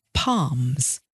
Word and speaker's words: palm: Well, apparently palms. That's P-A-L-M. One of those strange English pronunciations where you don't pronounce the L. You say palm as though there is an R in there instead. Palm.